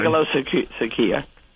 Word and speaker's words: megalopsychia: And I want to even know if Dr. Arne can even say this word, megalopsychia. – How do you say that? Practice that, right? – Yeah, how do you say that, Larry Arne? – Megalopsychia.